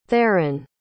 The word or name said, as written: theron